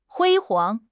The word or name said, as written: 辉煌